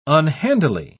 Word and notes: unhandily: British English

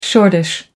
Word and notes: shortish: American English

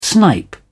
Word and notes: snipe: British English

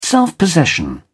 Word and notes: self-possession: British English

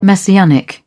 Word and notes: messianic: British English

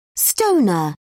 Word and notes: stoner: British English

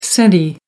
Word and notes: centi: American English

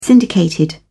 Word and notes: syndicated: British English

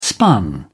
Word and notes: spun: British English

